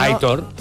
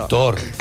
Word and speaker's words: Aitor: que dice, por favor, que Aitor se pronuncia como se lee. – Sí, no, Aitor. – Aitor, dice la acentuación es en la O. – En la O. Claro. Aitor.